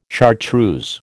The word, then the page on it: chartreuse: American English